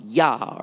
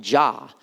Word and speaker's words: Yah: This is usually pronounced Yah or Jah.